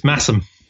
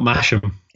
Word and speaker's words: Masham: It's Massam. Not Masham.